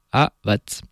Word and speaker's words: Aavat: Derrière, on a eu Aavat. Papy me disait souvent qu'en finlandais, il faut prononcer toutes les lettres. Donc là, il y a deux A, c'est Aavat.